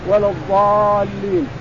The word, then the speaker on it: الضالين: الضاد تطلع من بين الشفتين ولا الضال لاحظ تطلع من بين الشفتين مغلظة ولا الضالين. بعض الناس ينطقها ضاد يعني يقول ولا الضال ينطقها ولا الضالين يخرج اللسان هذا ما ينبغي.